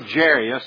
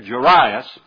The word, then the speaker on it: Jairus: And behold, there was a man named Jairus, or Jairus, if you want to get persnickety about the way you pronounce his name there, Jairus.